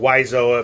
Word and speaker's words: Wiseau: Wiseau. – Or Wiseau, apparently it is pronounced.